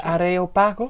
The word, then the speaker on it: Areopagos: Zeg ik dat goed? Dat weet je als... – De Areopagos? – Ja, Areopagos.